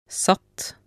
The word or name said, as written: satt